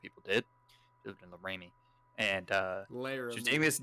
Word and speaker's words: Laramie: It's Laramie.